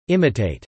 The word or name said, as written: imitate